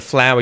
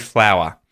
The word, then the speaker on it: flour: As you have probably just worked out, they are exactly the same pronunciation. Flour, I make bread with flour. I went into the garden and picked a flower. They're pronounced exactly the same way.